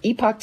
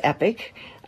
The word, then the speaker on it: Epoch: It's from the Epoch Times. And when we had Josh Phillip on yesterday, asked about the pronunciation, he said the U.S. pronunciation is epic. I think the British Canadian is epoch,